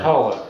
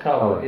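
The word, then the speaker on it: Koehler: It's K-O-E-H-L-E-R. – Oh, Kohler. Kohler.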